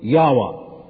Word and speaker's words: Yahweh: Four consonants, no vowels in the Hebrew language. Closest you can come to pronouncing it, Yahweh.